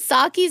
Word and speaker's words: Socci: Saki, like the drink, yeah. – But it's spelled S-O-C-C-I.